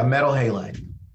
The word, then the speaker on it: metal halide: You mentioned the halide bulb. Am I saying that right? – Yeah, metal halide.